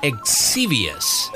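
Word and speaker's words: Xevious: It came out in 1982 by the company called Namco, and it's a game called Exevious.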